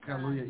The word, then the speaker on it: Kaluuya: So our first thing we're going to talk about is, hopefully I say his name, last name right, Daniel Kaluuya, the actor. Is that his title? Kaluuya. – Get Out. – Kaluuya, yeah, Get Out.